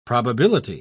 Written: probability

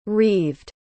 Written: reaved

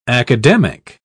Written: academic

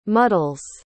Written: muddles

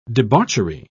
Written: debauchery

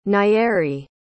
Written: Nyeri

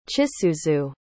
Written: chisuzu

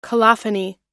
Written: colophony

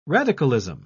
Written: radicalism

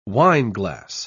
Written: wineglass

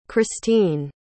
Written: kristine

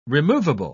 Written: removable

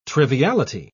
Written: triviality

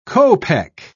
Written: kopeck

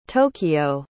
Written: tokio

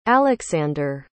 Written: aleksandur

